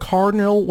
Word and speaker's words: Cardinale: And by the way, my last name is spelled Cardinal with an E at the end.